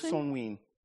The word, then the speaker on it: Samhain: It says Samhain if you write. – Also Samhain wird's geschrieben, ausgeschrieben. – But it's Samhain. – Samhain irgendwie ausgesprochen.